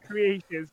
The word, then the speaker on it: Cretaceous: I was waiting for you to say it wrong. I knew you were going to say it wrong. It's Cretaceous.